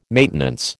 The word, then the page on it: maintenance: American English